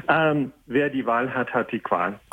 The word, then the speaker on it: Wer die Wahl hat, hat die Qual: Okay, not quite. Wer die Wahl hat, hat die Qual.